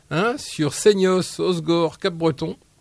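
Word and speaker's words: Hossegor: Et le 2 août à Osgore.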